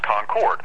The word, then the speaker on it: Concord: Yeah, uh, actually, in, in, the case of North Carolina, it's actually pronounced Concord.